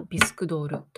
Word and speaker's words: 着せ替え人形: 着せ替え人形と書いて、文字は着せ替え人形と書いて、読むときはビスクドールと読むようですね。